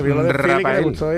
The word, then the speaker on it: Raphael: Hablamos del grandísimo, del enorme Rafael, que se pronuncia así a pesar de que se escribe Rafael, que tiene ahí una H en medio, que eso no vale para nada.